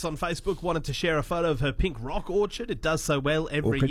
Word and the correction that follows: orchid: Orchid, excuse me.